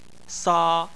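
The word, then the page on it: sa: tone 1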